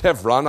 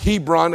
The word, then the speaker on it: Hebron: Now it's pronounced Hebron. I am pronouncing it correctly according to the Hebrew. You might pronounce it Hebron, H E B R O N, but the B has a V sound in Hebrew. Hebron.